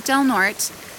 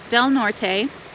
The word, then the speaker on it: Del Norte: pronunciation disclaimer, I realize that it looks like it should be pronounced Del Norte, but if you're from the valley or you live here, you know it's pronounced Del Norte, and just know that that's a local pronunciation.